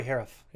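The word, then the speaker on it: Harouf: Corey Harouff. – You know, there Harouff. You go. Like Sheriff, but Harouff.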